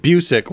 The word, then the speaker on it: Busiek: He said Busiek, like you sick.